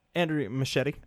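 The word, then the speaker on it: Muschietti: Machete has also, or how do you pronounce his name? – Andrew Machete. – Machete, okay. It sounded like the knife, but I knew it was spelled different. Anyway. – Yeah, it's like M-U-S-H-E-I-T-T-I or something.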